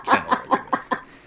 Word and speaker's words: Kennilworthy Whisp: Uh, this episode, we're going to be going through Chapter 7 through 10 of Quidditch Through the Ages by Kennilworthy Whisp, right? Is that how you pronounce it? – That's exactly how you pronounce it.